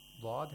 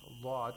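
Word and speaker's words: Lod (ض): In terms of the attributes or the characteristics of these two letters, the only difference is istitala, which Lod has, and Law doesn't. So you have to pronounce Lod with a little bit of stretching the consonant, lengthening the consonant a little.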